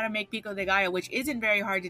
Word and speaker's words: pico de gallo: And then calling it pico de gallo, like, at least learn that a 2 L's is pronounced like a Y, you motherfucking assholes.